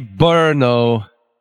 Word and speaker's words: Brno: B-R-N-O. How do you say that? – B-R-N-O? – Yeah, it's a city. – Brno? – Brno. All right. – Just how it's spelled.